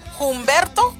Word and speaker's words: Humberto: Y luego, pues, hablando y platicando con personas, como si alguien se llama Humberto, se escribe con H, H-U, pero decimos Humberto, o nomás Humberto, no pronuncia el H.